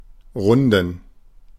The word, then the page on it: runden: /ˈʁʊndən/; [ˈʁʊndn̩]